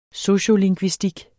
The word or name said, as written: sociolingvistik